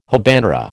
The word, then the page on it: habanera: American English